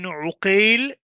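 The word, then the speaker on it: عُقَيْل: For example, Muhammad ibn Aqil بفتح العين and Muhammad ibn Uqail بضمها أي بضم العين.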